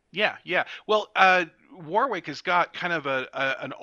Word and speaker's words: Warwick: For the record, although spelled Warwick, it is pronounced Warwick.